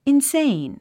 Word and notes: insane: inséin; インセェイン

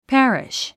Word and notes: parish: pǽriʃ; パァリッシュ

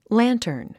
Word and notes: lantern: lǽntərn; ランタァン

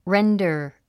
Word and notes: render: réndə r; レンダァ

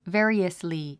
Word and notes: variously: vé ə riəsli; ベェリアスリィ

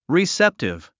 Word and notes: receptive: riséptiv; リィセップティブ

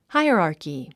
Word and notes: hierarchy: háiərὰːrki; ハイアラーキィ

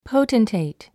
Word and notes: potentate: póutntèit; ポゥテンテェイト

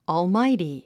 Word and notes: almighty: ɔːlmáiti; オールマイティ

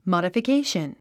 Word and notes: modification: mὰdəfikéiʃən; マダフィケーション

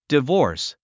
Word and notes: divorce: divɔ́ːrs; ディボース

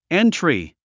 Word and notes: entry: éntri; エントリィ

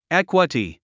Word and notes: equity: ékwəti; エクワティ

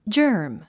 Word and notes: germ: dʒə́ː r m; ジャーム

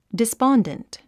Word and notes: despondent: dispɑ’ndənt; ディスポンデント

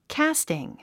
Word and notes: casting: kǽstiŋ; キャスティン

